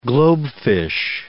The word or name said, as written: globefish